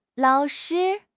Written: lao3 shi1

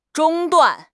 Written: 中断

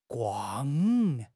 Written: guang4

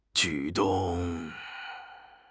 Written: 举动

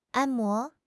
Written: 按摩

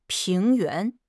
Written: 平原

